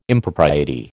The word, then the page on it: Impropriety: American English